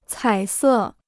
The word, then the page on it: 彩色: cǎi sè